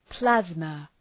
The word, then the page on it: plasma: ‘plæzmə